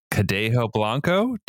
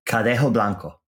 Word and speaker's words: Cadejo Blanco: And now you've got your new feature out, Cadejo Blanco. Did I get it right? – Very good. Yeah, I'll take that. That's a very good gringo pronunciation.